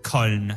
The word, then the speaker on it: Köln: Well, that's the English pronunciation. They have a different pronunciation for it in German. – Oh, really? – Yeah. – Oh wow. – Like it's Köln, I believe.